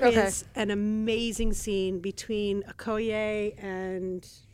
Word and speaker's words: Okoye: how do you pronounce that general's name? Oyoki? – Okoye. – Okoye.